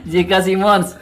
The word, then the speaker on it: JK Simmons: iya iya sekarang kan kiai ya dulu kan pengusaha Bapak Yusuf Kala Simon JK Simmons.